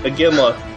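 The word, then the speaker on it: Iginla: French. Jerome Ignilia. – Aginla. – What? – Aginla. – Aginla?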